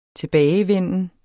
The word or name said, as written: tilbagevenden